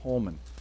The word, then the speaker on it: Holman: Holnam? Holnam? No. – Oh, I was looking at the Holman Christian Standard Bible. Holman. I can never say that right. Holnam is something else.